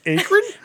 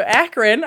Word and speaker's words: Akron: And you already mispronounced the city 40 minutes south as Akron? – I don't know. Okay, so Akron.